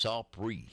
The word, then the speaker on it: Opry: Well, opera by day, honky-tonk by night. – You should have told them, Keith, you should have told them, we don't pronounce it opera. It's opry.